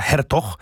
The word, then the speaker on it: Hertog: Wiem, że się wymawia Hertog, bo sprawdziłem w internecie.